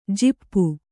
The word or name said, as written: ಝಾಪು